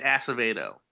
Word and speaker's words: Acevedo: Is the first syllable of his name ass? – I think so. – Acevedo. – It's Acevedo. – Good. – Oh, we're chill. – That's how it's pronounced.